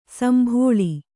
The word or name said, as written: ಸಂಭೋಳಿ